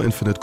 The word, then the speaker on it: Infinite: Und Kurz vor Release Battlefield 2042 und Halo Infinite kommen bald und wollen die Fans beruhigen.